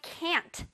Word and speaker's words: can't: 자, No, I can't 라고 하면 되겠습니다. 이것도 쌤이랑 배웠었어. 자, can 다음에 T를 붙여주면 돼요. Can't. Can't. 끝에 T 발음을 넣어주면 돼.